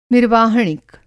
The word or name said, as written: निर्वाहणिक